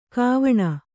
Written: ಕ್ವಣ

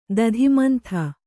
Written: ದಧಿಮಂಥ

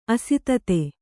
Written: ಅಸಾಧುತೆ

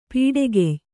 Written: ಪೊಟ್ಟುಗೆಯ್

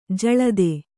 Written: ಜಲಧಿ

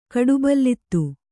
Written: ಕಡುಬಲ್ಲಿತ್ತು

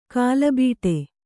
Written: ಕಲ್ಲುಬೆಟ್ಟ

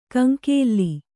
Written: ಕಂಕೇಲ್ಲಿ

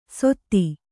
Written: ಸೊತ್ತಿ

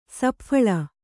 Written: ಸಪ್ಫಳ

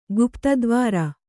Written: ಗುಪ್ತದ್ವಾರ

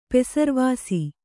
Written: ಪೆಸರ್ವಾಸಿ

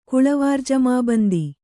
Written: ಕುಳವಾರ್ಜಮಾಬಂದಿ

